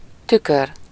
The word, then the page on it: miroir: /mi.ʁwaʁ/; /mi.ʁwɑʁ/